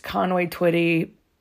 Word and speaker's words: Conway Twitty: Conway Twitty, I don't even know that's how you say his name. yeah, it is.